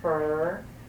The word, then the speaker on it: Fer: And on the back of the lifting is how to phonetically pronounce each name of God. Like Shem, S-H-E-M, Fer, F-E-R, Osh. Shem, Fer, Osh.